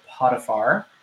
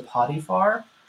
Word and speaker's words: Potiphar: So yes, Joseph has been sold and he now belongs to the master of Pharaoh's guard Potiphar pronounced as in I have diarrhea is the potty far.